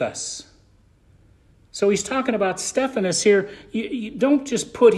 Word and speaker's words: Stephanus: Am I saying that right? Is it Stephanus or Stephanus? Stephanus.